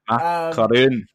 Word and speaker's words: Karun: That is Karun Chandok, like it's a macaroon. – Karun Chandok.